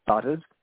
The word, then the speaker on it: Butters: Butters. – Sorry, can you spell that? – B-U-T-T-E-R-S, Butter with an S on it.